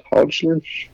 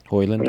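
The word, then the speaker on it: Højlund: Bit Rasmus how is it, Holland Hodgland. – Hoyland.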